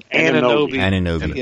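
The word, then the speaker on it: Anunoby: Help me on the pronunciation. – Ananobi. Yeah.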